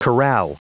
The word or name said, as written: corral